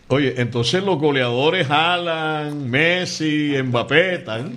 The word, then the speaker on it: Haaland: Yo escuché hoy a un amigo dominicano que estudió en Noruega y dijo que ese apellido se pronuncia Haaland.